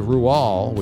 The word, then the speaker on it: Roald: 12.5% said Rual, which apparently is the correct pronunciation for Roald Dahl.